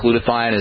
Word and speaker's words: glutathione: Building up your glutathione levels is very important. Glutathione, as you may remember from, we've talked about it a lot on the program, glutathione is your body's major detoxifying substance.